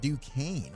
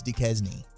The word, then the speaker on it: Duquesne: He said it's pronounced Duquesne. I'm like, dude, again. – Wait, Chris is in on the bit too? – We have another guy that doesn't know that it's Duquesne.